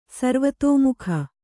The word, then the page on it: ಸರ್ವತೋಮುಖ: sarvatōmukha